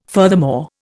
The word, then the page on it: furthermore: Indian